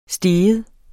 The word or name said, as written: steg